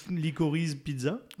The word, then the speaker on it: Licorice Pizza: c'est Likolitsi pizza. – Je crois que personne ne sait le prononcer. Licorice pizza.